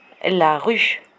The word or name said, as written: la rue